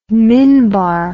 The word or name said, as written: minbar